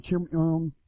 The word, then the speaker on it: Kim Jong-un: Kim Jong-un.